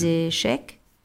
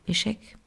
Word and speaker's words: Échecs: Ja, du musst, also das ist ausgegeben, die Normen sind ausgegeben über die FIDE, das ist die Fédération Internationale des Échecs. Ach so, wie spricht man denn das eigentlich aus? Schach auf Französisch. Échec, ja. Also das ist Échec.